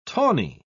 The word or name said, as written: tawny